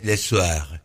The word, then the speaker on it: soir: La tarde es le soir. Le soir. Le soir. No soir como Adrián. – No. – La R es más gutural, le soir. – Y para escribirlo se escribe S-O-I-R.